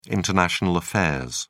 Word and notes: international affairs: British English

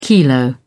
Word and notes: kilo: British English

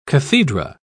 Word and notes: cathedra: British English

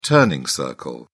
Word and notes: turning circle: British English

